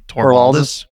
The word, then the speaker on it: Torvalds: Torvalds? – Yeah.